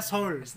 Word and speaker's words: Seoul: tulisannya Seoul, tapi bacanya Seoul.